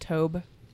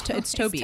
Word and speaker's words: Tobe: Wasn't it Tobe? – Well, no, it's Toby. It's Toby. But it's spelled T-O. It's spelled Tobe, but it's Toby.